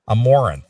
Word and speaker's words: Amouranth: Amouranth. Like Amore, as in like yeah. in love, Amouranth.